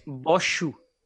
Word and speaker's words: Bose: del siglo XX, los bosones se llaman así en su nombre, pues siguen la estadística de, de En español se suele decir bosse pero realmente se pronuncia... De hecho, estuve buscando la pronunciación de su nombre esta mañana su nombre en, originariamente, ¿no? La pronunciación india es Satyendra Nat Satyendra Nat Bos. ¿Vale? Y pero así con la R fuerte. Wikipedia Fact es que es Boshu.